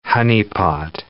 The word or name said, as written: honeypot